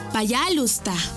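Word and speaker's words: пожалуйста: Y se dice payalusta. ¿Cómo? Payalusta. Una vez más. Payalusta. Y se escribe payalusta. P-A-Y-A-L-U-S-T-A.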